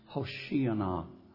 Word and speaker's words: Hosanna: In the Hebrew, it's pronounced Hoshiana.